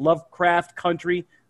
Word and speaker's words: Lovecraft Country: And uh, we, I gave you a review of Lovecraft Crunch. Ah, man, I just butchered that. Lovecraft Country last show,